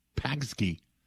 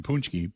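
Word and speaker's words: pączki: Which is, by the way, spelled P-A-C-Z-K-I, which would look more like Pagsky than Punschke, but that's the way it's reportedly pronounced.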